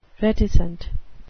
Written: reticent

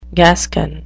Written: gascon

